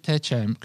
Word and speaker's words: Techem: Am I pronouncing it correct? – Techem, yeah. Techem. Very good, very good.